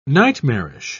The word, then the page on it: nightmarish: British English